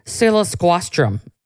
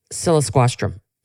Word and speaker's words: siliquastrum: And that's Cercis cilisquastrum. I'm going to spell that. C-E-R-C-I-S. Cilisquastrum. I feel like I'm in the spelling bee. S-I-L-I-Q-U-A-S-T-R-U-M. Cilisquastrum.